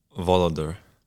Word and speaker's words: Voloder: And then give us a good pronunciation on your last name. – Voloder.